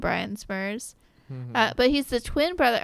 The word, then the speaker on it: Smrz: He has no vowels in his last name. – S-M-R-Z. – Yeah, it's pronounced Smurz.